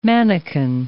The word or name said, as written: manikin